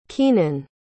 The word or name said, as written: kenan